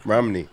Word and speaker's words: Romney: Romney. – Romney. – Romney. – Like Mitt. – Like Mitt, but not white.